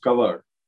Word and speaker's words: Kanwar: It's pronounced Kavar, but it's actually spelled Kanwar, Kanwar.